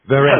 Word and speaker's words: Verein: and I don't know how you pronounce this word, V-E-R-E-I-N, Verein or something, with headquarters in St. Louis. Go ahead. – No, you go ahead. I think you got it right.